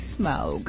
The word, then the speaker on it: Smaug: I asked Benedict Cumberbatch how to say it, and he said it's the Desolation of Smaug.